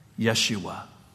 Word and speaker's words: Yeshua: The word salvation in the Hebrew language is this word, Yeshua. It's a name also. And if you were to pronounce the name Jesus in Hebrew, that is exactly how it is said and that is exactly how it is spelled.